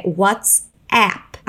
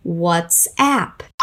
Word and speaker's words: WhatsApp: O aplicativo de mensagens que é famoso aqui no Brasil é WhatsApp. A pronúncia é um pouco diferente, notou? Vamos falar primeiro o nome do aplicativo. WhatsApp.